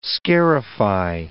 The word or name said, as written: scarify